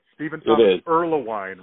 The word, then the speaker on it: Stephen Thomas Erlewine: Stephen Thomas Erlewine, right? Am I saying yeah. – it right? – Yeah, you're saying it right.